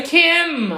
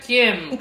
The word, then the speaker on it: Kim: É Kim. Você precisa colocar os dois lábios juntos e pronunciar Kim Kardashian. Kim não é, entendeu? É igual him, não é him.